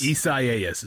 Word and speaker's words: Isaias: Isaias is how you say that. – Good Lord. I just thought it was Isaiah and they misspelled it.